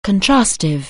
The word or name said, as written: contrastive